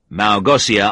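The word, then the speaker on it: Małgosia: Malgosia Armstrong, he said, pronouncing it with an S sound instead of the sh sound it should have.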